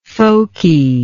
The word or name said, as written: folkiness